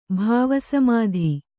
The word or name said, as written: ಭವಸ್ಮøತಿ